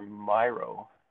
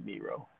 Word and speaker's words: Miro: But I'm also looking at two ways of delivering Jamboard by Google or Miro. Or Miro, depending on how you want to pronounce it.